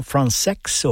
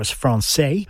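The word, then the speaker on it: Françaix: The 20th century composer Jean Francex, or Francais, I heard him say once on a radio program that he didn't care which way you pronounced it as long as you played his music.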